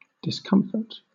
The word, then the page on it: discomfort: British English